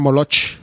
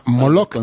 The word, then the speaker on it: Moloch: Estos son dioses demonios, o sea, son dioses muy oscuros que exigían sangre de muchos sacrificios, y no se pronuncia Moloch. Perdónenme ustedes, pero se llama Moloch.